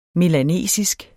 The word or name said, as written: melanesisk